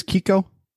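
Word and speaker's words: Keiko: I say it as Kiko. It's K-E-I-K-O.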